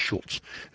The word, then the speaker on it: Schulze: And his name is Schultz, S-C-H-U-L-Z-E, Dr. Schultz. Schultz. I pronounce it Schultz, as if it's got a T in there.